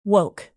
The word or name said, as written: woke